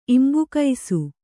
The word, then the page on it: ಇಂಬುಕೈಸು: Kannada